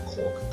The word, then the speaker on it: Caulk: И у одного фамилия Коук, да, типа C-A-U-L-K, ну, читается как Кок,